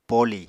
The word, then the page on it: poli: Apatani